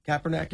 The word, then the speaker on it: Kaepernick: Remember, just like these democratic socialists today, Kaepernick, am I saying his name right? Kaepernick, yeah.